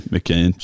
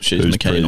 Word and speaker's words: McKeon: I've got no chance of pronouncing. McKeon. – She's McKeon on him. – Yeah, gotcha. Yeah. – That's how you remember it. – All right, she's my keen on him.